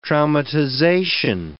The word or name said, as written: traumatization